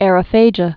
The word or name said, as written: aerophagias